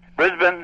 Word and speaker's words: Brisbane: Brisbane is the way we say it.